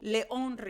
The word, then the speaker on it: León: It's called León River. I am pronouncing this the Spanish way.